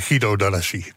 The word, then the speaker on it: Guido D'Alessi: Wat een naam, hoe spreek je het goed uit? – In Nederland zeggen we Guido D'Alessi.